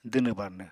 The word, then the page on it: dwnw-barnw: apatani